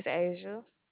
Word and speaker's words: Azha: My name is Asia. – Spell that for me. – A-Z-H-A.